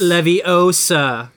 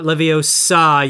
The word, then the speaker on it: Leviosa: It's Leviosa, not Leviosa, you ignorant folks. Emphasis on the O.